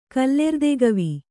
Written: ಕಲ್ಲೆರ್ದೆಗವಿ

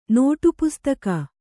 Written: ನೋಟುಪುಸ್ತಕ

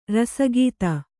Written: ರಸಿಕತೆ

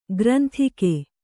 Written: ಗ್ರಂದಿಗೆ

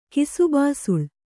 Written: ಕಿಸುಬಾಸುಳ್

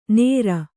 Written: ನೇರ

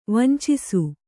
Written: ವಂಚಿಸು

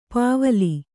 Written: ಪೂವಿಲ್

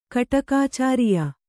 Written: ಕಟಕಾಚಾರಿಯ

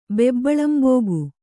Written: ಬೆಬ್ಬಳಂಬೋಗು

